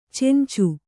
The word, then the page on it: ಚೆಂಚು: cencu